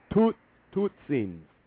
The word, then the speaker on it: Totsiens: T-O-T. S-I-E-N-S. Tootsins.